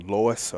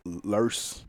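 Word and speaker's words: loess: And, you know, up in the hill country, you know, it's, this really deep, rich, what we call Lois or Lurse, as we pronounce it, you know, where you have these beautiful hardwoods and, you know, this, you know, right.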